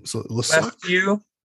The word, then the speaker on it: Lescu: Lescu. Like Romanian.